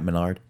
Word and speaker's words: Menard: I'm saying his name right, aren't I? Matt Menard? – Yeah.